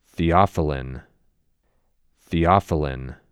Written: Theophylline

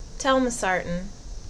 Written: Telmisartan

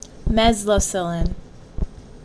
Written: Mezlocillin

